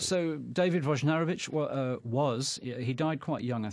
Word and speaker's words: Wojnarowicz: I was told two pronunciations, Wojnarowicz or Wojnarowicz. – I tried to announce this program, I think I called him Wojnarowicz, but so it's Wojnarowicz. So the first J is a Woj then, Wojnarowicz.